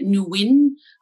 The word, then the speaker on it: Nguyen: It's G is silent. I'm happy to say it Nguyen, which is, whatever comes out of your mouth without the G, we're fine.